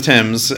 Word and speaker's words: Thames: It's pronounced the Thames, right? – The Thames. Yeah, it is the Thames.